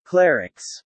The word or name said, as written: clerics